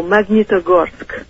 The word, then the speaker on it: Magnitogorsk: Oh, Magnitogorsk.